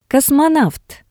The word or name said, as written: kosmonavt